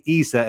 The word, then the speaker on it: Isa: And it was actually very interesting because in Ireland, in the Gaelic or Irish language, they say Isa as well. Yeah. They say it the same way, but obviously without the ayin, but the same pronunciation, Isa, they say.